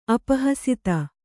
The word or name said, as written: ಅಪಹಸಿತ